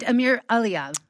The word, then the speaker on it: Amir Elia: And Amir Elia.